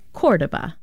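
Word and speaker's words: Córdoba: No disrespect to Mr. Spock, but other sources say he was born in 1138, and it's pronounced Cordoba.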